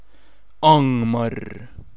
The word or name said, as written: Angmar